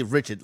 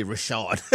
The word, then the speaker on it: Richard: Jalen Rashard. Yeah, he's from the Bayou. Yeah, you got to say you can't say Richard like it's spelled. He's from the Bayou, so you got to say Rashard.